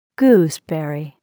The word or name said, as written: gooseberry